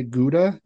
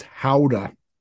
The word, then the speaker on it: Gouda: And I was like last week years old when I found out we say Gouda completely incorrectly. – How do you say it? – It's howdah. Howdah.